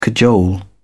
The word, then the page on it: cajole: British English